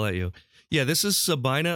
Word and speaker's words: Sabina: It's spelled like it should be Sabina, but from what I've heard, it's pronounced Sabina.